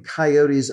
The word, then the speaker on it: coyote: And as best I can I could figure out, they seemed to think that a three-syllable word was giving coyotes a little bit too much credit. So they shortened the pronunciation to two syllables, coyote, and that pronunciation ended up sort of in the hinterland and in much of the rural part of the United States.